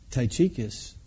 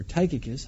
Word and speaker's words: Tychicus: Now, by the way, it's thought that Onesimus and Tychicus, or Tychicus, however you prefer to pronounce that, were the ones who brought this letter, along with the letter to the Colossian church.